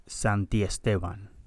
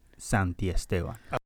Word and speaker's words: Santiesteban: Santiesteban. – Dang. Say that again. – Luis Santiesteban.